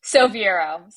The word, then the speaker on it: Severo: Severo. So the O Severo. Is like a hard O.